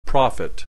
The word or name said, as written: prophet